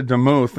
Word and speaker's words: DeMuth: Now, the reason I'm bringing this up is because this is the winner on the right, and her name is Lisa DeMuth, and that's how I pronounce it. It's D-E-M-U-T-H, DeMuth, okay?